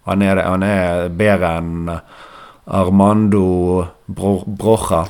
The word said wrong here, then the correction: Broja: Nei, det var ikke Brocha du skulle uttale. Broja. Armando. Han, eh, han, har vært bedre enn han i det siste. Jon har jo lært noe, det skal være Armando Bloya.